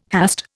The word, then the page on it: hast: Indian